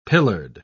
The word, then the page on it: pillared: British English